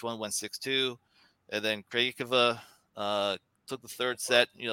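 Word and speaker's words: Krejčíková: Listening to the broadcasters, I think it's pronounced Krajicova. I think it's more of the closer name pronunciation.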